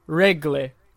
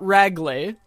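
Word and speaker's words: Rögle: But literally, Nils Höglander told us that the pronunciation of it in Swedish, unless he's just messing with these two Canadian reporters that he's talking to, it is regle. That's how you say it. It's not ragle.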